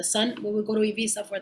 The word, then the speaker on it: Ibiza: If you want to make people roll your R's, you have to, los españoles te van a decir, o sea, gilipollas, tiene que ser Ibiza, es Ibiza.